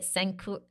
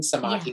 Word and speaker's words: Samadhi: Is it Sankura? How do you say focus? Sankura? Am I saying, wait, how do I say it? – Oh, focus or concentration? Samadhi.